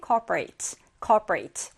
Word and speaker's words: corporate: 첫 번째 우리를 기다리고 있는 단어는 corporate or corporate. 둘 다 발음이 가능해요.